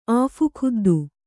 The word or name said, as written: ಆಫುಖುದ್ದು